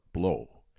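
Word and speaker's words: Blå: Blow. And it's spelled, if you're in North America, B-L-A, with a little circle above, like an umlaut would be.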